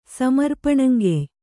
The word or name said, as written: ಸಮರ್ಪಣಂಗೆಯ್